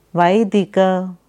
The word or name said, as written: Vaidika